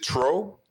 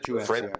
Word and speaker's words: Truax: And if he does hurt Truax, is it Truax? – Truax. – I think it's Truax. Caleb Truax. – But it's pronounced Truax. But nonetheless.